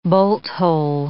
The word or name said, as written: bolt-hole